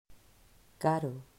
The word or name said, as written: Caro